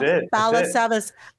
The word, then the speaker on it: Balasabas: Rob Balasabas.